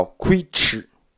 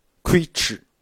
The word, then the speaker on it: 龟兹: 这个地方龟兹语，它的这个音的发音就叫龟吃，龟吃，龟兹。